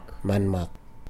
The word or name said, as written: manmak